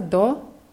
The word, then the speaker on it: до: Es wird DO geschrieben. Dieses O wird aber nicht so stark ausgesprochen. Es ist so ein bisschen geschluckt. Как добраться до почты, also ein bisschen wie A wird es ausgesprochen.